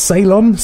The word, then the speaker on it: Ceylon: I think this is pronounced Ceylon, C-E-Y-L-O-N. – Yeah.